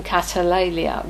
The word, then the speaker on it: katalalia: Not to join them in catalalia, which is the Greek for backbiting and evil speaking, literally speaking down. It's spelt K-A-T-A-L-A-L-I-A.